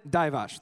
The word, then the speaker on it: Divac: Vlad Divac, I think, is his name. He played Divac.